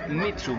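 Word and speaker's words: Mitzu: ya se acerca, ya está aquí el buen fin y lo hace con Mitsu. Mitsu se escribe con Z, M-I-T-Z-U.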